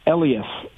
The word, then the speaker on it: Elias: Elias, Elias. What are we saying? – Oh man, we've only heard seven different pronunciations, and it's Elias.